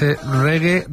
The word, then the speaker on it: reggae: que nosotros la escribimos R-E-G-G-A-E, pero en realidad ellos, la primera vez que lo escribieron, era un poco más como se pronuncia, que es reggae, reggae, o sea, R-E-G-G-A-Y, y sonaba así.